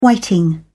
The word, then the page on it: whiting: British English